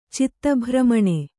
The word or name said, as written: ಚಿತ್ತಭ್ರಮಣೆ